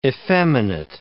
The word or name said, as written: effeminate